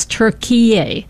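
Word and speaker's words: Türkiye: So it's spelled it's Turkiye, and I guess the Turkish pronounce it as Turkiye, and so that's how they want to be known, and they don't want it to be associated with our Thanksgiving meal of turkey.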